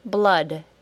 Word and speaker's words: blood: Number six, blood, B-L-O-O-D, blood. Can you get that one? The spelling is unusual for the sound, but it is the short U, as in sun. Listen and compare. Blood, sun. It is the same sound. Number 7. This is the last one today. Bread. B-R-E-A-D.